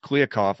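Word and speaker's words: Kliavkoff: The Pac-12 just hired a new commissioner, George Kliakoff. I hope I said that right. Probably. – I think you did. It's difficult, but I think you got it right.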